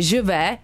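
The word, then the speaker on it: Je veux: No, objednal si píseň od Zaz, což je francouzská zpěvačka a single se jmenuje Živé. Vyslovuju to správně? Aha, na mě teda kývá, že Živé. – Ano. – Živ. – Řekni nám, jak se to správně vyslovuje.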